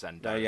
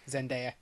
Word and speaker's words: Zendaya: Apparently it's Zendaya. – Yeah. – So, I think Lamara tells us off every time, doesn't she? Yeah, do you know what? I've already forgotten how you say it. – Zendaya. – It's Daya.